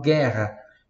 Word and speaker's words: guerra: G-U-E-R-R-A. Só que em português eu falo guerra. Em italiano eu falo guerra. Tá? Então eu vou ter que ter ali o som do U.